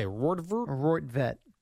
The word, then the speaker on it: Roethlisberger: Ben how do you say? – Roethlisberger.